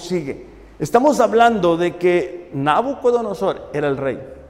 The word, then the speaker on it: Nabucodonosor: En el libro de Daniel encontramos la historia del rey Nabucodonosor. ¿Lo dije bien? Nabucodonosor. Muy bien.